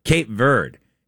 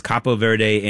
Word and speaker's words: Cape Verde: Cape Verde beating, and that is the way you pronounce it. It's Cabo Verde in Spanish, but according to all the sites I looked at in English are supposed to say Cape Verde.